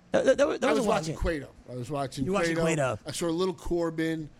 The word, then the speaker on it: Cueto: I said, yes, you pronounced the U in Cueto, but not in Quintana, just so you know.